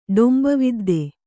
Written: ಡೊಂಬವಿದ್ದೆ

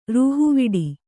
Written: ರೂಹುವಡೆ

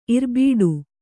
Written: ಇರ್ಬೀಡು

